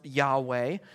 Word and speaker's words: Yahweh: It's the Hebrew word that's typically pronounced Yahweh.